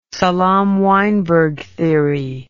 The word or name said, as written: Salam Weinberg theory